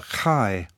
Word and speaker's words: chi: But there is another Greek letter, the chi, which is something like the ch in lock.